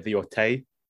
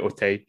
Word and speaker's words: Auteuil: So Canal Plus in charge, they kind of backed and financed in some part the creation of the Otay. I don't know if I'm pronouncing that right. It's spelled AUTEUIL. So I'm gonna go with Otay.